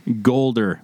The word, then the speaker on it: Goulder: Golder. There it is. You want to spell that? – G-O-U-L-D-E-R. – G-O-U-L-D-E-R.